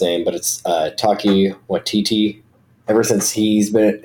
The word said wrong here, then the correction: Taika: Taika, yeah.